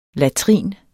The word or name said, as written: latrinet